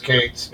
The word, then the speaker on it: Cates: Is it Coates? – I thought it was Coates. – I think it's Phoebe Cates, yeah. – Is it Cates or Coates? – I think it's pronounced Cates, yeah.